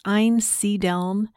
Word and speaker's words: Einsiedeln: Apparently, I've been mispronouncing that. And on the previous episode of the podcast, I was pronouncing it Einsiedeln. Mm. It's very awkward for me. It's Einsiedeln.